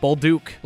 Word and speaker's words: Bolduc: Zachary, I'm going to butcher it. – I think Bolduc? – It's Bolduc. – Bolduc. – Bolduc. Zachary Bolduc.